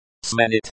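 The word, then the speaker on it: сменить: Smenit. Pronunciation. Sminit. Stress on the second syllable.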